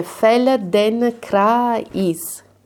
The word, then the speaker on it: Feldenkrais: Bene, e adesso mettiamo tutto insieme e pronunciate le sillabe che avete provato a pronunciare fino adesso, tutte insieme. Quindi sentite fel, den, cra, is.